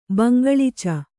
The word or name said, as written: ಬಂಗಳಿಚ